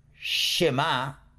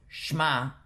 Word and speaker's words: shema: So, for example, in the word shema, you've got two dots under the shin. We do not pronounce it shma, but rather shma, shema.